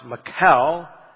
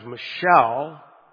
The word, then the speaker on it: Michal: And then Michael can be pronounced. It could be pronounced Michal. We pronounce it sometimes Michelle.